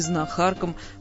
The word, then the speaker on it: знахарка: Ударение там же, где и в мужском аналоге слова, на первом слоге. Знахарь, знахарка.